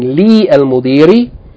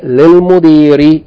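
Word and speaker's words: lil-mudiri (للمدير): Whenever it's added to a word that has alif lam, we lose in writing and in pronunciation the alif from alif lam. So instead of saying li al-mudiri, we say lil-mudiri al-jadidi.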